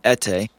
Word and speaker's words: Ete: I have another name called Robert, and that would be my name that is much easier to pronounce than Ete. I tell people my name and they say, "What? Huh? I don't understand." So just to get it over with, Ete is E-T-E.